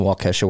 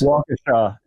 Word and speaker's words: Waukesha: Milwaukee Bitcoin Meetup in Waukesha, but it's in Waukesha, Wisconsin. Join us. – Waukesha. It's pronounced Waukesha.